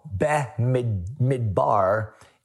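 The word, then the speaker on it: Be-Midbar (במדבר): The book is called Be-Midbar. I think it's the way you pronounce it in Hebrew.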